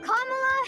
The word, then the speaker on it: Kamala: It's not Kamala. – It's Kamala. – Kamala Harris. – Well, the senator says she's used to people mispronouncing her name, but says it's easy to remember. Just combine comma with law, and you get Kamala.